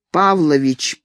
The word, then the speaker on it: Павлович: «Тимофей Павлович Пнин», что означает «Тимоти, сын Пола». Отчество имеет свое ударение на первом слоге, а дальше все «смайзен».